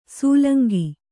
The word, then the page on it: ಸೂಲಂಗಿ: sūlangi